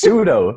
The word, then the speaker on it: pseudo: Pseudo. Isn't that how you say it? – Pseudo. It's pseudo.